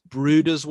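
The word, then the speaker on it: Bryde: The books that I mostly paid attention to when I was younger, they put all of them into one species, Bruder's whale. It's spelt Brides, but it's pronounced Bruder after a Scandinavian,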